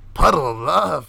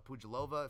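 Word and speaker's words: Pudilová: Also, to staying in the theme of favorites, albeit a slight favorite, money's getting tighter on this favorite, but Lucy Puddle of Love for the old school listeners. Pujalova, if you want to be proper and actually pronounce her name the right way, over Yunnan Wu, or Wu Yunnan, if you want to pronounce that name the right way.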